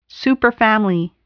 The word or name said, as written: superfamily